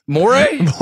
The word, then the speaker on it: Morey: The second part is I get a kick out of how you pronounce his last name. – Morey? – Morey, yeah. – That's how I've always said it. – I know. As long as we've known each other, it's always been Morey.